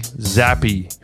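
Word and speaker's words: Zappe: It's Bailey Zappy, pronounced like happy.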